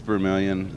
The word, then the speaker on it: Vermilion: We were in Montrose yesterday, and of course, is that the East Fork Vermilion River? Am I saying that right? – Yes, that's the East Vermilion, and you go on further west, you got a Middle Vermilion, and then a West Vermilion, but the East Vermilion took Montrose.